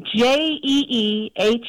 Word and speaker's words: jee-haw: J-E-E-H-A-W. Jee-haw. Like yee-haw, but with a J.